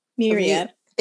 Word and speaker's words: myriad: I know there's a Maraud. – Myriad. – Thank you. I knew I said that wrong, so I paused for correction.